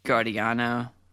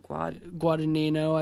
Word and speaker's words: Guadagnino: Guadagnino, I think, Guadagnino. Is how it's pronounced.